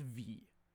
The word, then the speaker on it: Viy: And that is the 1967 Soviet horror film, V. I think it's V is how you pronounce it. V-I-Y.